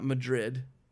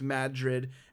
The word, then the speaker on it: Madrid: We end up in a town called Madrid, which is not Madrid, but it's specifically stated that it's pronounced Madrid.